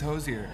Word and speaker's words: Hozier: And it's Hozier, am I correct? – It is Hozier. Yeah, rhymes with cozier.